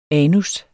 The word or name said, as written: anus